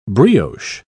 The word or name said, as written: brioche